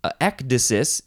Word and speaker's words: ecdysis: Ecdysis is how you pronounce it in English, at least.